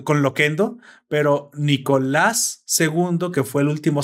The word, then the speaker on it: Nicolás: Pero no vaya el acento, cabrón, va en la A es Nicolás.